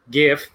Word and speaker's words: GIF: GIF hard G, uh, that explains how to get into Google Groups.